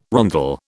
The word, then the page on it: rondel: British English